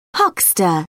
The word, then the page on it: huckster: British English